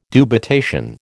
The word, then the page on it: dubitation: American English